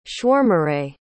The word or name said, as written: schwarmerei